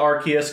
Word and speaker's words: Arceus: I'm going to call it Arceus because I'm pretty sure that's how they pronounced it in all the marketing.